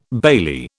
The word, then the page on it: bailey: British English